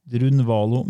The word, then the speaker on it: Drunvalo: Men nei, han, Drunvalo, rett fram, det er U-N-V-A-L-O. Og Melchisidek er e e e M-E-L-C-H-I-Z-E-D-E-K. Drunvalo Melchisidek.